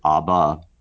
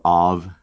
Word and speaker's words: Av: We're more familiar with the Aramaic Abba, father. This is a shortened form, just A-B, Ab, or Av, depending on how you want to pronounce it.